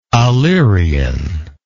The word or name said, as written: alerion